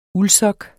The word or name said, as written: uldsok